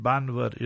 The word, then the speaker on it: Bhanvad: B-H-A-N-V-A-D. It's spelled Banvad, but they pronounce it Banvad, you see.